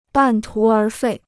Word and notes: 半途而废: bàn tú ér fèi

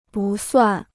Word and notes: 不算: bù suàn

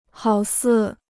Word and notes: 好似: hǎo sì

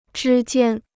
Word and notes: 之间: zhī jiān

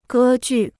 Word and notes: 歌剧: gē jù

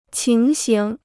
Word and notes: 情形: qíng xing

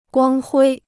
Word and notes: 光辉: guāng huī

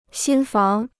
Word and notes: 新房: xīn fáng

